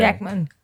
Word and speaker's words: Schechtman: Hoe spreek je haar naam nou uit? Want ze is Amerikaans, toch? – Ja, volgens mij Schechtman. – Okay. Schechtman.